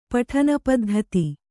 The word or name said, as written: ಪಠನಪದ್ಧತಿ